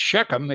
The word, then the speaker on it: Shechem: It's Shechem. It's spelled S-H-E-K-E-M, not S-C-H-E-M.